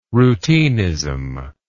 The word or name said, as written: routinism